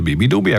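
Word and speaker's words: beabadoobee: si scrive Beaba Dubi ma si legge Bibi Dubi,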